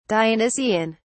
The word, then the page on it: dionysian: British English